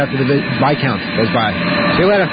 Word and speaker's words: viscount: viscount is. – V-I-S-C-O-U-N-T? – Yes. – You mean Viscount? – Is that how they pronounce it? – Uh-huh.